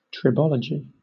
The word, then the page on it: tribology: British English